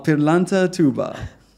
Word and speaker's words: Pirlanta Toubba: Oh, Pirlanta Toubba.